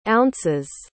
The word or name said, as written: ounces